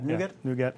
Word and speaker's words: NuGet: Right, and that gallery that and the tool that's I never know how to pronounce it, NuGet. – Yeah, NuGet.